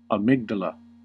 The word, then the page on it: amygdala: American English